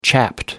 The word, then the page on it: chapped: American English